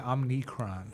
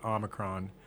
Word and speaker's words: Omicron: Yeah, it's also hard because I keep wanting to say Omnicron. – Yeah, I had to look up like four times how to pronounce it because I just felt wrong, but Omicron.